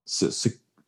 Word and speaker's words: Serkis: Andy's, I always say his last name wrong. Circus? Snoke. – Circus. – Gollum. Andy Serkis.